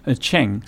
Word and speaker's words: Cheng: Cheng. C-H-E-N-G. Cheng. Just Cheng. – Like it sounds.